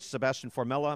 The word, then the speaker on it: Sebastian Formella: And he beat, was it Sebastian Formella? Am I getting the name right? – Yeah. – Formella on our air.